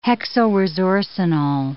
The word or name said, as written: hexylresorcinol